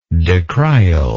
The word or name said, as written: decrial